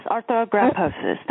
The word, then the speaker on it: arthrogryposis: So, Jennifer, you told me that you have a family history of, now how do you pronounce it? Arthrogryposis? – Yes, arthrogryposis. That's correct.